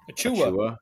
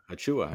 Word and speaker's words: Achiuwa: And then Malachi Flynn and Precious Achiuwa. – Achiuwa.